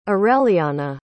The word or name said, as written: aureliana